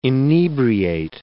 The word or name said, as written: inebriate